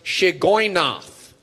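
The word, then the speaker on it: Shigionoth: This word with the S is pronounced Shigoynoth. Someone say Shigoynoth.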